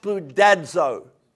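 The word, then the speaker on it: spoudazo (σπουδάζω): Spoudazo is actually the word, spoudazo. Everyone say that word? And it literally is pronounced spoudazo.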